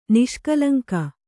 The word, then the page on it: ನಿಷ್ಕಲಂಕ: niṣkalaŋka